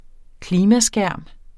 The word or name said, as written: klimaskærm